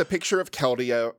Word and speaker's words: Keldeo: Or no, it was Keldeo. – Oh, yeah, it was Keldeon. Keldeo? – No, just Keldeo. – Keldeo, no N. – Okay. – Keldeon is my favorite Eeveelution. – No N, accent on the O.